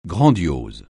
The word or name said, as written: grandiose